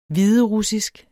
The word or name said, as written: hviderussisk